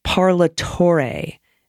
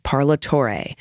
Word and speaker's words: Parlatore: It's Parlatore. The fourth symbol rhymes with day. Parlatore.